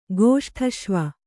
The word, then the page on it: ಗೋಷ್ಠಶ್ವ: gōṣṭhaśva